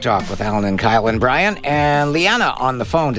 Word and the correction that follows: Leona: Did I pronounce that correctly, Leanna? – It's Leona, but that's Leona, fine.